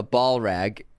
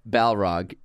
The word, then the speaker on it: Balrog: We also got a lot of hate for calling it a ball rag. It's a Balrog. It's the real pronunciation. People were writing ball, like a two-word ball rag.